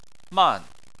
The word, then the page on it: man: 4